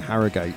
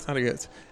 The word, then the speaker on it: Harrogate: I've been pronouncing it wrong all week. I've been saying Harrogate. – Oh, like we got somebody last night, actually. Well, let's hear him now. That's how you say Harrogate.